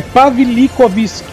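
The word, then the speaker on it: Pawlikowski: Mas Guerra Fria é Pavel Pavilo... Eu também me perco nele. É Pavlikovsky. – Então.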